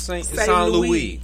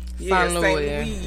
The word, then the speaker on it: St. Louis: What is supposed to be St. Louis? – It's St. Louis. St. Louis.